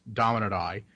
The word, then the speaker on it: Dominate Eye: And you can follow me on Twitter at, it's spelled Dominate EYE. It is pronounced Dominate I.